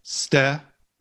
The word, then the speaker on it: Stephanie: Stephanie. – Oh my God. – Three syllables, dude.